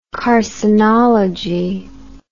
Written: carcinological

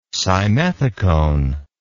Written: simethicone

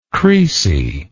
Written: creasy

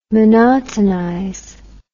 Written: monotonize

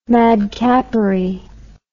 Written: madcappery

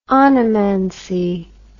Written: onomancy

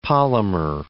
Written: polymer

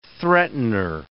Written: threatener

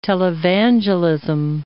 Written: televangelism

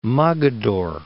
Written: Mogador

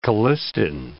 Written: colistin

